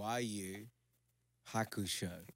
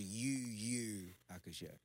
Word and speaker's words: Yu Hakusho: No. It's Yu Hakusho. – Yu Haku. – It's I'm telling you what it is. Yu twice Hakusho. – Yu Hakusho. – Yeah, but it's pronounced Yu Hakusho.